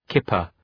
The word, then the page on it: kipper: ‘kıpər